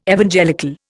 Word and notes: Evangelical: Indian

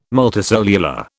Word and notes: multicellular: British English

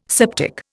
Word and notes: septic: Indian

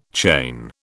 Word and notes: chain: British English

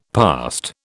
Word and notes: past: British English

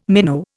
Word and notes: minnow: Indian

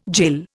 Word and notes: jill: Indian

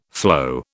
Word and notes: floe: British English

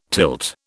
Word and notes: tilt: British English

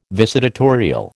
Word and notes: visitatorial: American English

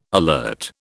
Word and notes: alert: British English

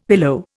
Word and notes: pillow: Indian

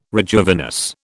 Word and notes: rejuvenesce: British English

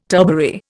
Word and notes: turbary: Indian

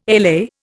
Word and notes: LA: Indian

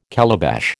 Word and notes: calabash: American English

